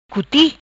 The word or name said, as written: Cuti